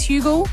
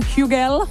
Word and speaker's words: Hugel: His name is Hugel. Oh, I'm saying it wrong. Hugel.